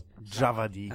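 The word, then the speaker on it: Djawadi: Javadi.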